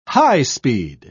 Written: high-speed

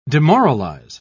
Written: demoralize

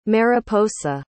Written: mariposa

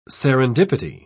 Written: serendipity

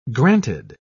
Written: granted

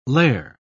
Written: lair

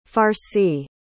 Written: farci

